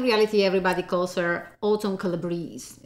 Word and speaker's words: Calabrese: First of all, the name Autumn Calabrese, okay? The right pronunciation is Calabrese because it's absolutely a family name that come from Calabria, that is a region of Italy.